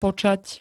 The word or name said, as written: počať